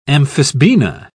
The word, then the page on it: amphisbaena: British English